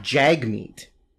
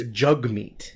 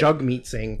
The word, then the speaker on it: Jagmeet: Well, it's not Jagmeet. It's Jugmeet. – Oh, that sounds dirty. – I know, but that's what it is. – I like it. It's Jugmeet Singh, not Jagmeet Singh, because whiteys don't know how to say it.